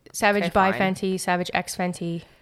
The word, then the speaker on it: Savage X Fenty: This is where you don't say the X, right? It's Savage X Fenty.